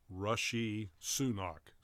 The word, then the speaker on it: Rishi Sunak: Yeah, I just looked at the pronunciation as well, and it's Rishi Sunak.